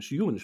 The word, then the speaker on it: Ewan: Ja, ähm, krass, bin ich erstmal überhaupt gar nicht drauf gekommen, worauf du hinaus wolltest, aber ähm, ja, Erstmal, äh, nennst, nennst du ihn e-, Even? Ich hätte ich sage immer Jun. – Oder Juen, Juen, Juen, Juen spricht man das aus.